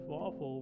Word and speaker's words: Fawful: Fawful, which rhymes with awful, is also a recurring antagonist, and can be a sidekick to the main antagonist.